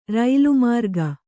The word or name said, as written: ರೈಲುಮಾರ್ಗ